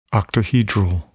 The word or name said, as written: octahedral